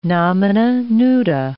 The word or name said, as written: nomina nuda